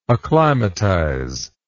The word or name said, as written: acclimatizer